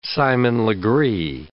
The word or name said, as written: Simon Legree